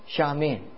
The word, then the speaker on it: Xiamen: Is that how you say it? Xiamen? Xiamen, yeah.